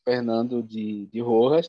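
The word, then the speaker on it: Fernando de Rojas: Então, é, obrigado, professora Rosiane, pela, pela provocação, né, para trazer e falar um pouco mais sobre o, o Fernando de, de Rojas, estava correta a, ah, a pronúncia, sim.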